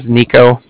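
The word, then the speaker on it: Niko: My name is Nico. That's N-I-K-O.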